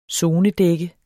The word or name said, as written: sonedække